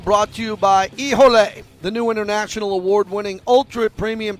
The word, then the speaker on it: Hijole: Ijole is smooth, easy to drink, straight on the rocks in your favorite cocktail. It's spelled H-I-J-O-L-E. Ijole.